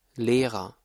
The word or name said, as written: Lehrer